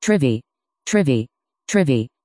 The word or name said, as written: Trivy